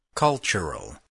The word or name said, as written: cultural